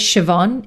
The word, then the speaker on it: Siobhan: My name is Siobhan. It rhymes with Yvonne, and Shiv can sometimes just be easier.